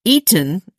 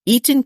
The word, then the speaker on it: Iten: Eaton, and I believe that's how it's pronounced, I-T-E-N, Eaton, Kenya, sits at 2,400 feet elevation in the Rift Valley in Kenya.